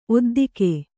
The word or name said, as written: ಒದ್ದಿಕೆ